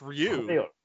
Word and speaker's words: Ryu: It's just Ro. – Yeah. It's just Ryu. Ryu. – Ryu. – Ryu's good at fighting, man.